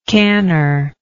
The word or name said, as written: canner